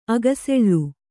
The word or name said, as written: ಅಗಸೆಳ್ಳು